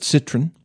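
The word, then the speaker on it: Citroën: It's not like Citroen. You ever heard of Citroen? Well, I thought it was Citroen. And some people would say Citroen, but the way it was spelled didn't jive with that. So, but Citroen is the proper way to say that.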